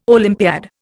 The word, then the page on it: Olympiad: Indian